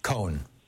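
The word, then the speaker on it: Cohn: Then finally McCarthy and Ray, you said Ray Khan or Ray Cohen? – Cohen. One syllable.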